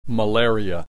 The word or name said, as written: malaria